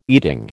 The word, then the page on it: eating: American English